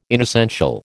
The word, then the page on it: inessential: American English